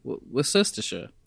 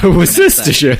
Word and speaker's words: Worcestershire: Mostly Worcestershire. That's not Worcestershire. – That's not how you pronounce that word.